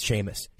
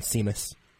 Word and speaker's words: Sheamus: It's Sheamus. Sheamus. It's like the shame. I'm shaming us for not knowing how to pronounce. – Seamus.